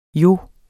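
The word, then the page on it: jo: Danish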